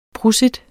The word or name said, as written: prosit